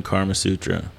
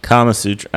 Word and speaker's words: Kama Sutra: You were creating your own Karma Sutra as a kid. – Karma Sutra? – Yeah. Kama Sutra.